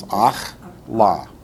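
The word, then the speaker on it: Akhla (אכלה): I know akal, but this is akal. Akala. – Just two syllables. Akhla. Right.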